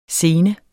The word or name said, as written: sene